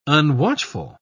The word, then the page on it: unwatchful: British English